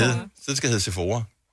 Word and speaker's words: Sephora: først der, min hjerne har aldrig nogensinde brugt tid på at skulle spekulere over, hvordan udtales S-E-P-H-O-R-A. Mm. Sephora og Sephora, I don't know. – Men det var selvfølgelig Sephora.